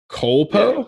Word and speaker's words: Culpo: How do you say that last name? Am I saying that correctly? – Culpo? Yeah. Olivia Culpo.